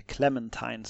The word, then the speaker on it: Clementine: Då, det var det året då han också gifte sig med sin fru Clementine. Eller Clementine ska man säkert uttala det om det inte är en frukt och utan en människa.